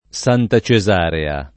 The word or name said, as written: Santa Cesarea